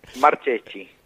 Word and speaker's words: Marcheschi: Se escribe con CH, SCH y latina. – Sí. – Y como es italiano, se dice Marcheschi, pero es Marcheschi.